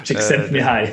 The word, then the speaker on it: Csikszentmihalyi: Csikszentmihalyi.